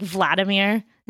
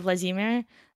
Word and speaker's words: Vladimir: I just really, I really Americaned that because like Americans always put the syllable, the stress on the first syllable, like Vladimir instead of Vladimir.